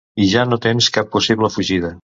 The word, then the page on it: tens: català